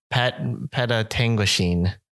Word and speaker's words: Petawawa: Petawawa.